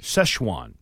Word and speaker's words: Szechuan: Szechuan. – Yeah. – Glazed burnt ends. Mm-hmm. Chunks of tender brisket tossed in a... What'd you say? Szechuan? – Szechuan. – Szechuan.